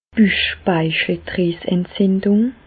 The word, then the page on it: d' Büchspeicheldrieseentzindùng: Alsatian; Bas-Rhin; Reichshoffen